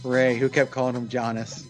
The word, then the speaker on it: Giannis: Mr. Antetokounmpo himself, Braun Strowman's favorite member of the Milwaukee Bucks is Giannis. – Yes, and thank you very much for pronouncing it properly, or at least as properly as we know.